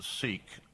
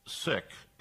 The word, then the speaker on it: Sikh: I think I'm right to pronounce that Sikh. We pronounce it Sikh often, mm-hmm. but it's Sikh,